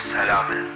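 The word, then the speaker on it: Salameh: M-O-H-A-M-M-A-D. How you say your last name? – My last name is Salame. – Sal, oh, okay. Sal, A-M-E-H.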